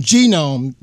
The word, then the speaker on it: Genome: Genome.